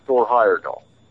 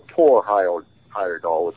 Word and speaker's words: Thor Heyerdahl: Well, one of the early inspirations for me was Thor Heyerdahl, or Thor Heyerdahl, if you want to use the Scandinavian pronunciation, and his Kon-Tiki voyage, where he proved that people could have sailed from Polynesia to South America to populate the Americas.